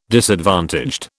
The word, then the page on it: disadvantaged: British English